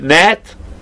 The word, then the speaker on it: gnat: I think you pronounce it gnat, if I'm not mistaken. Yeah, I don't think you pronounce the G, I don't know.